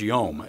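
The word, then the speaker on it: yom (יום): And what's interesting is this word day in the Hebrew is yom. It's spelled Y-O-M, but it means a time, a season, a division of time.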